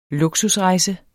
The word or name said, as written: Luksusrejse